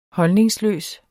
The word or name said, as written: holdningsløs